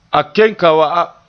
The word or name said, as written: ken